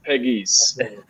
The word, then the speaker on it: Pegues: What I always tell people Pegasus. Is there's always three brothers, and they called us the three little piggies. So it's pronounced Peggies.